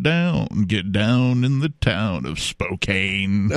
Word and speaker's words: Spokane: And we have been talking about this article from several sources, but this one is from the Spokesman Review out of Spokane, or Spokane, Washington, if you want to mispronounce it. – Spokane. – Yeah, that's the mispronunciation. Yeah, it's Spokane when you're there.